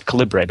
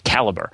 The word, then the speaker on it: Calibre: I used to pronounce it Calibre, but it turns out it's supposed to be pronounced Calibre.